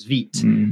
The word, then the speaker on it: Vite: Also, I think if you use the build tool, is it Vite or Vite? Vite, I think. – Vite. – I think it's Vite. – It's the French pronunciation. – French, right? Yes, yeah, so it's Vite.